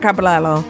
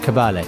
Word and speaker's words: Caballé: I'm going to say her name wrong. Montserrat Caballero. – Caballé.